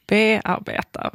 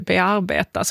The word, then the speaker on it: bearbeta: Och varför tar du då helt plötsligt betoning i bearbeta? Varför säger vi inte bearbeta? Som vore rimligt.